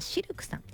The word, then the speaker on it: CILQ: そして南青山にフレンチレストランシルクさん、 CILQ と書いてシルクさん、そして霞が関エビ専門店シュリンプガーデンさん、そしてもう一つ日本橋高島屋さん新館にございますカフェババさん VAV とか言ってね、カフェババさんということでね、素晴らしいお店ばかり。